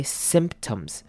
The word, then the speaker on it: symptoms: 一开头的 I 要念成短母音 I， symptoms， symptoms， symptoms 后面 T-O-M-S 呢，我们要用轻音带过 tums， tums，所以这个字的重音在前面 symptoms， symptoms， and many other symptoms that an addict goes through。